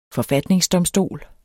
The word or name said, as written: forfatningsdomstol